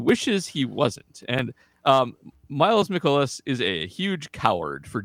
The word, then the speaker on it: Mikolas: I want to, aside from those two, I want to talk about Miles Mikolas. I don't know how to say his last name, because I watch Mikolas. – Mikolas. – Miles very Mikolas. Greek. Very Greek.